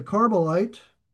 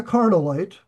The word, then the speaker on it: Carmelite: His second, Kileab, and I may butcher these names, forgive me, of Abigail, the wife of Nabal, the Carmelite, not the Carnalite, but the Carmelite.